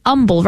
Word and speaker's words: Humble: Goes to St. Mary Magdalene, and it's an humble, right? Humble. There's no H in humble, right? – Well, there is an H, but it is silent.